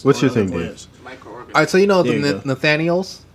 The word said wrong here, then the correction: Neanderthals: humans, what we call Neanderthals. – In what? I said it wrong. – You said Neanderthals. – What did I say? – Nathaniels. That's a name.